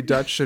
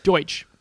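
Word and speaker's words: Deutch: But you know what you need with an ancient premise movie is two young actors with ancient sounding names, Zoe Dutch and Glenn Powell. – Deutsch.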